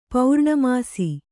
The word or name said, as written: ಪೂರ್ಣಮಾಸ